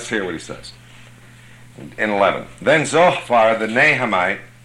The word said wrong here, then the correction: Naamathite: Naamathite, I can't pronounce that. Naamathite, thank you.